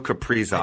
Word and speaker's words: Kaprizov: It's Kaprizov, right? – It is. It's Kirill Kaprizov.